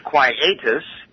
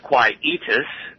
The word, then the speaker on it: quietus: The word of the day is quietus, also pronounced quietus, Q-U-I-E-T-U-S. One more time, Q-U-I-E-T-U-S.